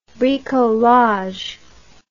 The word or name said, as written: bricoleur